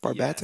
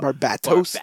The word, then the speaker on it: Barbatos: Am I saying that right? Barbatos? – Yes, yes. – I feel like the bat needs to be emphasized. Barbatos?